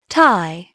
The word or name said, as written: tie